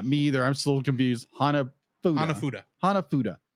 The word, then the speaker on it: Hanafuda: Okay, Hanafuda. – I know how to pronounce it because that's what Nintendo made when the company was founded in the 1800s.